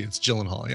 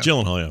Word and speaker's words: Gyllenhaal: By the way, they confirmed it's a hard G. No, it's a soft G. It's Gyllenhaal. Yeah. – Gyllenhaal.